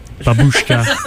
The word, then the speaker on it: Babushka: Babushka.